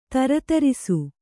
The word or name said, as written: ಥರಥರಿಸು